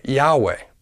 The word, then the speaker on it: Yahweh: In sounding out these four vowels, Yahweh, the first two, Yah, are equivalent to the syllable Yah. The last two, Weh, are equal to the syllable Weh. When all vowels are pronounced together, it sounds like Yahweh, or Yahweh.